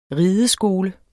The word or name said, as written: rideskole